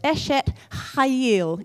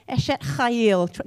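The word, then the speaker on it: Eshet Chayil (אשת חיל): So the phrase we read as the wife of noble character is, in Hebrew, I guess, is pronounced Eshet Chayil, except you've got to do the CH as a ch. Eshet Chayil. Give it a go. Eshet Chayil.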